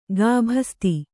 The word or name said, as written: ಗುಬ್ಬಿಸೌತೆ